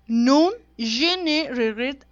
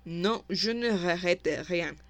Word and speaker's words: Non, je ne regrette rien: Es Nun je ne regrette de Edith Piaf. Y A la ver, verdad... déjame decirte. Así no se dice. Se dice Non, je ne regrette rien.